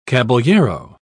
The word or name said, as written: caballero